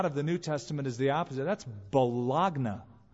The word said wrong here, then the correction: baloney: Oh, excuse me, baloney it's pronounced.